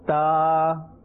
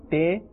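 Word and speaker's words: ta (ت): Ta. Ta. Ta. Here as well, do not say te. Rather, the correct pronunciation is ta.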